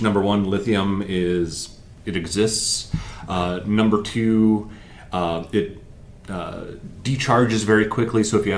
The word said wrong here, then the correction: discharge: Discharge, thank you.